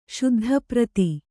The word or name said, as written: ಶುದ್ಧಪ್ರತಿ